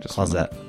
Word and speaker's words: Clauset: Clauset is pronounced Clauset, right? – Clauset.